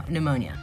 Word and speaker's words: pneumonia: So, am I saying that right? Ammonia? Yeah, no, pneumonia.